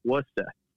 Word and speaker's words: Worcester: So I understand that it's pronounced Worcester. So I want to make sure that I'm saying it right when I show up. – I always tell people, just ignore the R's and you'll be fine.